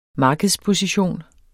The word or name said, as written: markedsposition